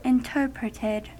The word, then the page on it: interpreted: American English